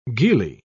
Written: gillie

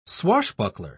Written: swashbuckler

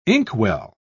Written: inkwell

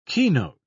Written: keynote

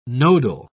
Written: nodal

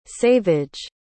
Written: saveage

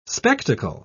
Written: spectacle

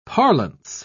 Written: parlance

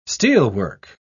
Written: steelwork

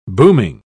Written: booming

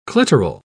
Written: clitoral

